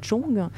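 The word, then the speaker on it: zhong (钟): L'horloge, tout simplement parce que en chinois, l'horloge se prononce zhong.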